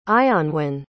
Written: ionwen

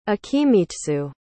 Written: akimitsu

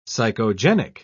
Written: psychogenic

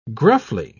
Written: gruffly

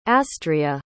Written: astria

